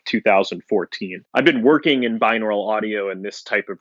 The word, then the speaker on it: binaural: binaural audio. – That's correct. Binaural. Also very well pronounced.